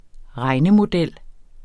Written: regnemodel

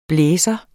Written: BLAZER

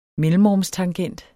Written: mellemrumstangent

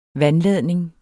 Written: vandladning

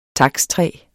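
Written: takstræ

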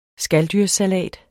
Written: skaldyrssalat

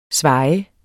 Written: svaje

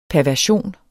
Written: perversion

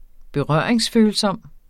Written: berøringsfølsom

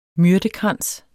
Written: myrtekrans

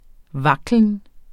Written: vaklen

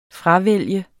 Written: fravælge

